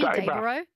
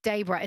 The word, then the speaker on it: Dayboro: Dayboro. Is that right? – Daybra. Yeah. – Okay. – I know. – Daybra. It's spelled O-R-O through me. – It is. – Yeah. It should be Daybra, B-R-A-H. – Yeah. Everyone south of the border calls it Dayboro, but yep.